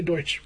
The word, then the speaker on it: Deutsch: Barry Deutsch. – Deutsch? – Deutsch. – Is it? – I would say so because there's like this television attorney named Ronnie Deutsch.